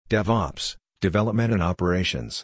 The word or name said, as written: DevOps (Development and Operations)